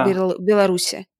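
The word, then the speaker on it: Беларусь: Беларуси, правильно? – Да. Я не из тех людей, которые топят за вот это правильное произношение названия.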